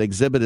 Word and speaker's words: Xzibit: Enter team exhibit as a code, but exhibit is spelled X-Z-I-B-I-T. So it's capital T, capital X, team exhibit, X-Z-I-B-I-T, and you'll have it in NFL Street 2 for the original Xbox.